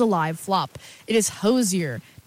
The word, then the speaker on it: Hozier: But he was like, how do you pronounce it? Like, once and for all, you know, let's cut the rumors. Why do you say it? He goes, Hozier.